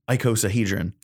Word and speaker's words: icosahedron: Icosahedron. I finally know how to say it. – You got it correct. – An icosahedron. – End of the year. – Or is it isocahedron? – Oh, no.